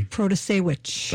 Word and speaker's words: Protasiewicz: Vote for Judge Janice Protasiewicz. Did I say that right? – Protasiewicz. – Thank you.